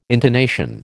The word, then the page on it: intonation: American English